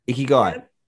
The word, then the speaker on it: Ikigai: Yeah, it's very Ikigai, is that how you say it? – Yeah, Ikigai.